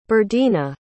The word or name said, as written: berdina